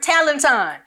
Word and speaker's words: talenton: It literally comes, the word talent literally comes from the Greek word talenton. Almost rhymes with Peloton.